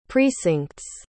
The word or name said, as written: precincts